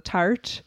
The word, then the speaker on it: Tarte: talking about the pronunciation of this earlier, but the Tarte concealer.